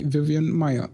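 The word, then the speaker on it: Vivian Maier: Ja, aber so wie ich das in der fotografischen äh, Umgebung gehört habe bis jetzt, äh, heißt sie Vivian Meyer.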